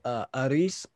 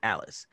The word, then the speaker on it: Aris: One of them, his name is Aris, but if you say it the way the Japanese pronounce English, it sounds like Alice.